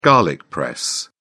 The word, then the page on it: garlic press: British English